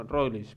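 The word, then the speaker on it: Roglič: y llegó el hombre con su alegría y dijo, Roglic, primo Roglic, y salí y se fue, y ya, eh, y así, eh.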